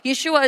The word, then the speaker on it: Yeshua: Yeshua is the original pronunciation for Jesus.